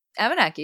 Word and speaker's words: Abenaki: You also sing, I'm going to maybe butcher my pronunciation of this in Abenaki. – Abenaki, yeah.